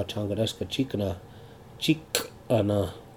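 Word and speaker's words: Cankdeska Cikana: Eventually we end up going back to Fort Totten, and now Little Hoop Community College is now Changadeska Chikana Community College, is how they say it, and over there.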